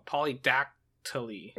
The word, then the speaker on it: polydactyly: Oh, polydactyly. – Right? That's the pronunciation? – Yeah. – Polydactyly. – Yeah, polydactyly. – Polydactyly. All right. D-A-C? – Uh-huh.